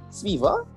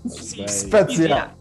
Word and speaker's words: Spezia: Sviva? – Spezia.